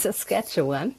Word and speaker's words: Saskatchewan: Also der heißt Saskatchewan.